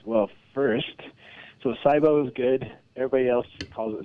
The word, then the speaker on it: SIBO: SIBO. I guess I'm saying that correctly, S-I-B-O, which stands for Small Intestinal Bacterial Overgrowth.